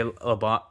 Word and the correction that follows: Labanc: LeBlanc, Labanc, Labanc. I always say his name wrong.